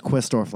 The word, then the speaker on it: Quistorf: Anyway, it's pronounced Quistorf like Twistorf.